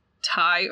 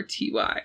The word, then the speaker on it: Ty: I think I do have to find out if it's Ty or TY. I'll be right back. All right, guys, it's official. It's pronounced Ty, like short for Tyler.